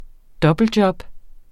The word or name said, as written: dobbeltjob